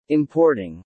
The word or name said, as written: importing